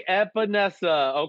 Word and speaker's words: Epenesa: I'm going to AJ Epenesa.